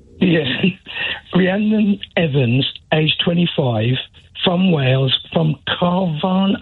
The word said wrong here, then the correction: Carnarvon: I spell that, C-A-R-N-A-R-V-A-O-O-N. – Carnarvon. – Carnarvon.